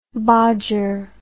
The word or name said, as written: bodger